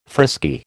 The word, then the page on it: frisky: American English